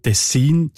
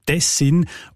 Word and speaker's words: Tessin: Und manchmal ist es im Laufe der Sprachgeschichte passiert, dass bei Fremdwörtern die Betonung nach einer Zeit eingedeutscht gekommen ist, also eben auf die erste Silbe verlagert. Das scheint im Fall von Tessin zu Tessin offenbar eben vor allem noch an dieser Region passiert sein.